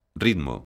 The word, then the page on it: ritmo: galega